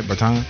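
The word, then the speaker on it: Bertāns: He's not a great defensive player as well as uh, you know Bertans is the that's how you pronounce it, right? Bertans. Yeah, that's okay.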